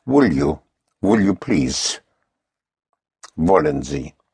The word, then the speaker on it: wollen: Wollen is spelled W-O-L-L-E-N. Again, the W has the sound of a V. Wollen. Wollen Sie. Sie means you. Spelled capital S-I-E.